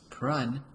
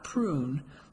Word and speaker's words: Prun: Prun, pronounced Prun, not prune, like generations of kids had called the former educators,